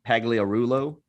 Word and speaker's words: Pagliarulo: Do want to give a shout-out to Mike Pagliarulo.